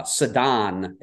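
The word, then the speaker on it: Ceddanne: Sedan is the pronunciation. Let's get this right all year.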